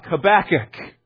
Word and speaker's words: Habakkuk: But when we say Habakkuk, we're recognizing that the first K we come to is the end of one syllable, and then the second K is the start of another syllable, so that his name is Habakkuk.